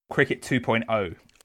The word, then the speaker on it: Cricket 2.0: Can we first check with you how you should pronounce the title to technophobes like us? – Cricket 2.0. Not zero.